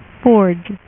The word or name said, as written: forge